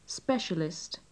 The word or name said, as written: specialist